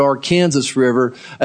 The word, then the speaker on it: Arkansas: Now, the Arkansas River is spelled like Arkansas, but we pronounce it Arkansas River as it goes out of Colorado through Kansas.